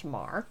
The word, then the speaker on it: Meagher: This book is about one of the more remarkable men to come out of the 19th century, Thomas Francis Marr, and it's spelled M-E-A-G-H-E-R. The author kindly and helpfully provided that it is pronounced Marr, who lived on three continents, fought in two wars, and died a mystery in Montana.